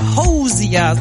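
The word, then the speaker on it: Hozier: Hosier, so spricht man den aus.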